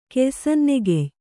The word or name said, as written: ಕೆಯ್ಸನ್ನೆಗೆಯ್